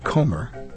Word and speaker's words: Comer: We heard voices of Robert Morse, Liberace, and Anjanette Comer, as that's how she pronounces her name.